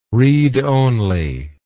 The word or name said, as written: read only